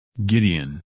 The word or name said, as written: Gideon